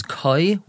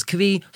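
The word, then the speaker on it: כוי: Now, really, the correct way to pronounce this word is koi, which is an animal that's a safek whether it's a chai or behema, but colloquially it's pronounced as kvi, so therefore we're going to use that pronunciation.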